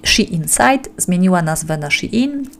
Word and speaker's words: Shein: To jest właśnie poprzednik Shein, stąd właśnie ta sugerowana wymowa Shein, a nie Shane, ale mniejsza o to.